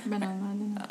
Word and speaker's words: Benalmádena: She lives in the south of Spain, just up the coast in a town called Benalmádena. Did I pronounce that right? – Yeah, Benalmádena.